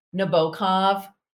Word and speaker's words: Nabokov: So I love Nabokov, and that's how his name is pronounced, not Nabokov, Nabokov, and Vladimir Nabokov.